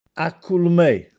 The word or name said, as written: Acculummè